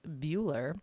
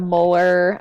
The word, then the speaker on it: Mueller: All right, today we are interviewing Julia Mueller, pronounced Bueller. I had that note there because I'm like, I'm gonna butcher this name. As in Ferris Bueller, am I saying this right? – Yes.